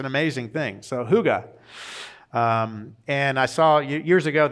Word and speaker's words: hygge: It's spelled H-Y-G-G-E. So you would think it's hyge or, you know, but it's pronounced hygge.